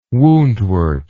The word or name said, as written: woundwort